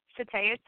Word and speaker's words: Shataya: Okay, well, Shataya Sims, let me spell it, S-H-A-T as in Tom, A-Y-A, last name Sims, S-I, M as in Mary, M as in Mary, S.